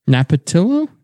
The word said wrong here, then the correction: Napolitano: Is that how you pronounce it? – Andrew Napolitano. – Napolitano. There we go.